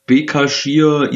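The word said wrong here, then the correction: Başakşehir: Istanbul oder so, der Erdogan-Verein. – Genau, Basaksehir, Basaksehir.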